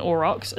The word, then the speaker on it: aurochs: Well, I can recommend having a Google of an aurochs. It's not spelt how I thought it was spelt either. – I pronounced it wrong. – No, you spelled it pronounced it perfectly. It's A-U-R-O-C-H-S. Yeah. But I spelled it O-R-O-X, but the Google knew what I was talking about.